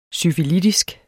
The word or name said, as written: syfilitisk